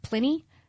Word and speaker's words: Pliny: Oh, hey, we've been saying his name wrong this whole time. It's apparently pronounced Pliny.